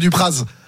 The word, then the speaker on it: Dupraz: Bon, oui, mais après, je pense que droit de réponse, Monsieur Dupraz. – Ouais. Donc vous n'êtes pas savoyard, vous, par contre, ni au savoyard, parce que normalement on prononce pas le Z, mais c'est pas grave.